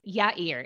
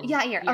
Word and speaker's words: Yair: How do you pronounce? – Yair. It's Hebrew. So it's like Yah. – Oh, Yair. Okay. Yair.